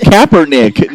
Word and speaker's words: Kaepernick: It's Kaepernick, not Pumpernickel.